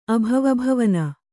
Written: ಅಭವಭವನ

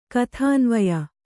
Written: ಕಥಾನ್ವಯ

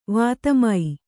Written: ವಾತಮೈ

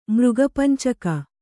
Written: ಮೃಗಪಂಚಕ

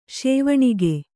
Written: ಸೋವನಿಗ

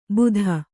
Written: ಭೂತ

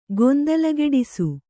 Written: ಗೊಂದಲಗೆಡಿಸು

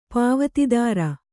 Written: ಪಾವತಿದಾರ

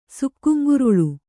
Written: ಸುಕ್ಕುಂಗುರುಳು

